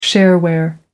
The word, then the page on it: shareware: American English